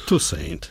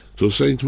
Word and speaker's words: Toussaint: jak właściwie go ci Amerykanie wymawiają. – Toussaint. – Toussaint mówią, to jak Toussaint, napisane jest?